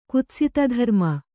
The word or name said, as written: ಕುತ್ಸಿತಧರ್ಮ